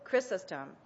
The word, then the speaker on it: Chrysostom: I ask you how to pronounce it. Chrysostom. He said it's pronounced both ways.